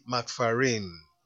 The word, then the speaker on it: McFerrin: Yes, this song was done by a guy named Bobby McFarrin.